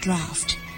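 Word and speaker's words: draught: draft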